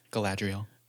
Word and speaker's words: Galadriel: And I'll probably butcher this, but Lady Galadriel, is that right? – Galadriel.